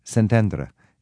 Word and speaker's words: Szentendre: How do you say it in Hungarian? – In Hungarian, that would be Szentendre. – Szentendre.